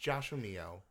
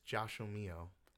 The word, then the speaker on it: Joshomio: So it's pronounced Joshomio, but you spell it as Josh, then O-M-I-O.